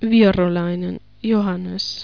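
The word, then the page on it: Virolainen, Johannes: Finnish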